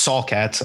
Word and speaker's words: Saul Katz: Tell me right now, the name Sal Katz sounds like someone you would do like really sketchy shit with. – I mean, it's pronounced Saul Katz, I think.